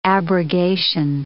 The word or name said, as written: abrogation